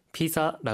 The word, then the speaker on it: ピザ: はい、ピザ 라 고할 때 그 때 ザ 발 음 은 Z 발 음이 니 注意해 주 시고 요。